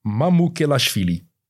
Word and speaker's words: Mamukelashvili: Sandro Mamelouk... Mame, ik kan het zelfs niet uitspreken. Mamukelashvili.